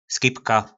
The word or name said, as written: Skibka